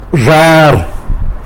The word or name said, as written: zhar